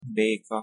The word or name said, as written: beka